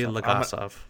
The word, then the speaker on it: Legasov: Legasov. – Valery Legasov. – I'm going to butcher every character's name, but... – you can just say the actor. – Jared Harris. – Yeah, Jared Harris, who plays Legasov, who you'll know who he is in a second.